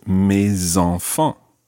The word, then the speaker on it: mes enfants: And it ends in an S. So normally the S is silent, but when it's followed by a vowel, it becomes mes, in this case, mes enfants.